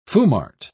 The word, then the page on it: foumart: British English